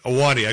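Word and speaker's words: Ahwahnee: We stayed at the Wauna Inn, the famous old one that they use some of the Awani. Is it the Awani? – Awani. I couldn't pronounce it correctly there.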